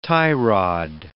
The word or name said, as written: tie-rod